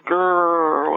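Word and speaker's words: Gurr: Her repairman was Joseph Gerr. That's spelled G-U-R, and I call him Joseph Gerr with all more R's on the end.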